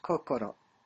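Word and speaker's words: kokoro (こころ): In the character for Shin also has another pronunciation, and that pronunciation is kokoro.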